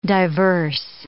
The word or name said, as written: diverse